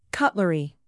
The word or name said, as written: cutlery